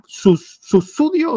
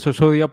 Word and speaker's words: Sussudio: Adrián Susudio, pues me suelen decir, si quieres pronunciarlo en inglés, como Phil Collins lo pronuncia, él lo decía Susurio, pero con Susudio mismo ya está bien.